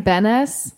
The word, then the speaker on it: Benes: Well, how do you say her last name? Benes? – Yeah.